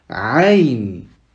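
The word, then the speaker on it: Aïn (عين): Aïn. Donc là, ça vient de la gorge. Aïn.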